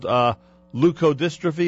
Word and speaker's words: leukodystrophy: I know, if I'm pronouncing it correctly, it's called leukodystrophy. Is that the proper pronunciation? – That is the proper pronunciation.